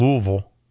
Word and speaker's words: Louisville: I keep wanting to say Louisville, right? But you're supposed to say it like you got marbles in your mouth. You're not pronouncing it Louisville.